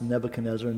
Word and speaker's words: Nebuchadnezzar: It helped me remember kind of how it's pronounced, Nebuchadnezzar, never can squeeze her.